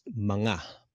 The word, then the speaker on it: mga: Like that word right there, MGA, that's pronounced manga.